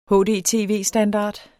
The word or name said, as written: hdtv-standard